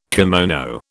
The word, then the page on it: kimono: British English